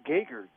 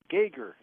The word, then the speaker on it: Geiger: Well, Beatrice, it's Geiger. – Geiger. – G-E-R is how it's spelled. But, boy, that sure doesn't help you. So, Geiger, yeah.